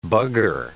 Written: bugger

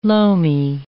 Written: loamy

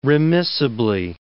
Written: remissibly

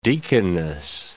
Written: deaconess

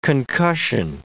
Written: concussion

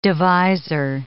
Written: divisor

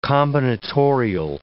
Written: combinatorial

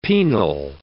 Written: penal

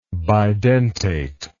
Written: bidentate

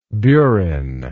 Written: burinist

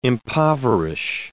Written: impoverish

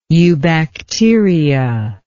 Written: eubacteria

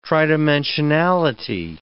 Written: tridimensionality

